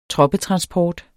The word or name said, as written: troppetransport